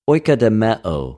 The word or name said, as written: οἰκοδομεω